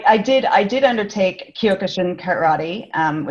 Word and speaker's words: Kyokushin: and I'm going to say this wrong, Kyokushin karate, is that how you say it? Kyokushin. Kyokushin, karate as well.